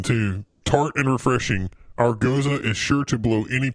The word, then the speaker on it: Gose: and it's not Gose, it's Goza. – Goza? Oh, well, see, that shows how much I know. – I just saw Goza.